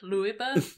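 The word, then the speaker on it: Leube: We saw in the first match Yuta Nakashima and Oscar, I don't know if it's Oscar Luiba. Luiba? – Yeah. – Would be kind of a German pronunciation that I'm guessing. – I believe that is correct.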